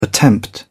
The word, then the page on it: attempt: British English